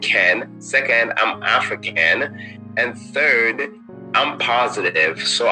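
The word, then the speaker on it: Afriken: So every time you hear the term African when we're speaking, it's spelled A-F-R-I and then Ken, K-E-N.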